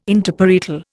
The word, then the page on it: Interparietal: Indian